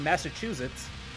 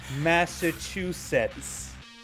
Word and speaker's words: Massachusetts: Did I say it right? I can't even tell anymore. – You did not. – Massachusetts. – There you go. – Is that correct? – Yes. That time was good.